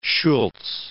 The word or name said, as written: Schultz